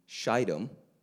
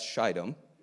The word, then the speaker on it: Shittim: And Joshua, the son of Nun, sent 2 men secretly into Shittim, Long I, as spies. If you ever read the Bible publicly, just Google it, okay? Because you can make a big mistake. So that's Shittim.